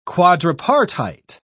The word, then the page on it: quadripartite: British English